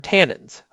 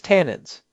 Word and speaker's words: tannins: First word, tannins. I mentioned them earlier. Pronounced T-A-N. Ends.